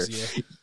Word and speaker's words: RZA: Is it RZA? – RZA is the actor. RZA.